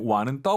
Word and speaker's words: 晚: 지금 저희가 발음해드린 완은 WAN 삼성입니다. 저녁, 완샹할 때 완을 단독으로 쓰면 늦다 이런 뜻이에요.